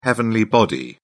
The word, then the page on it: heavenly body: British English